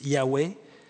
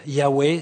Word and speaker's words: Yahweh: Si ici il est dit, le mot qui est utilisé c'est le tétragramme, le mot sacré des Juifs, Yahweh, que les témoins de Jéhovah orthographient ou prononcent Jéhovah, mais on pense que c'était plus Yahweh.